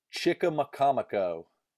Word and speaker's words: Chickamaconico: Chickamaconico. Chickamaconico. Chickamaconico. – Pretty much just how it's spelt, so that's not that bad.